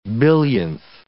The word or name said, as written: billionth